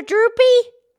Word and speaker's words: drupe: Because they're droopy? – No, it's spelled D-R-U-P-E, droop, because it has that big stone, the pit.